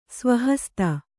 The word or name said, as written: ಸ್ವಹಸ್ತ